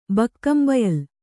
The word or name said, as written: ಬಕ್ಕಂಬಯಲ್